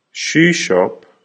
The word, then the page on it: shoe shop: American English